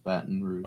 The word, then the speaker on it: Baton Rouge: All right, Baton Rouge. It's three syllables, Baton Rouge.